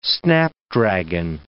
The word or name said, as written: snapdragon